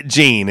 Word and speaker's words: Gein: Ed Gein. It's spelled G-E-I-N.